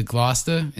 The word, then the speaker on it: Gloucester: So a shout out to Gloucester.